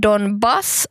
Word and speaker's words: Donbass: Och sen Donbass har betoning på andra stavelsen.